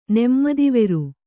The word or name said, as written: ನೆಮ್ಮದಿವೆರು